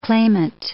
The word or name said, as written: claimant